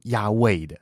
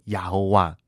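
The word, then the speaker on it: 雅威: 因为当学者去还原整个当时的母音的时候，根据查考，其实呢是应该读成亚卫的。所以在旧约当中，其实神的名字是亚卫，而不是把这个阿多奈的母音呢硬生生的塞到原本这个亚卫的子音的部分，念成亚侯哇。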